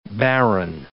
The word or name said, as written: baron